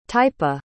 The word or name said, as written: taipa